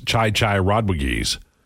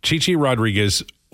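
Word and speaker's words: Chi Chi Rodriguez: Les Nessman mispronounces Chai Chai Rodriguez. Man. Chichi Rodriguez was a brilliant golfer, but he's probably best known for Les Nessman's mispronunciation on WKRP.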